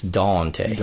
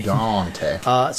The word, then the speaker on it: Daunte: And it's spelled D-A-U-N-T-E, which makes it even cooler. It's Dante. – Dante.